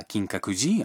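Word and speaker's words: Kinkakuji (金閣寺): Essayons maintenant de dire quelque chose d'autre que l'on veut faire.Imaginons que nous voulions aller à Kinkakuji, un temple à Kyoto.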